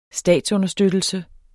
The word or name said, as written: statsunderstøttelse